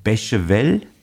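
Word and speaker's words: Beychevelle: Äh, wie zum Beispiel spricht man aus, das ist Bechevel. Ist das richtig? Ja, das hast du, das hast du eigentlich im Prinzip schon ganz gut hingekriegt.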